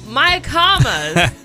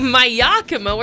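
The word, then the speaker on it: Mayacamas: And then I found the sign and I was like, "Oh, my commas." I was like, "Well, my Yakima, where'd you get that from?" And he said, "It's how it's spelled." And I was like, "No, it's my commas."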